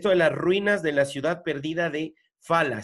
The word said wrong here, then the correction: Falan: estoy pronunciando bien. ¿Cómo es, Luisa? Sí, Falan. – Falan.